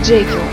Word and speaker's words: Джейкил: Лео Викс мне подсказывает, что произносится это как Джейкил.